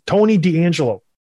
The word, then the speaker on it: Tony DeAngelo: Tony DeAngelo, nailed it.